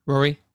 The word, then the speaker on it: Ruari: Ruari without the B.